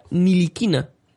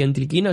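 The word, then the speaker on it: Ntilikina: se pronuncia Nilikina, La T por lo visto es muda. Un abrazo, sí. Cracks. Un abrazo para ti también. Gracias por avisar, porque yo decía Entriquina, así que ahora...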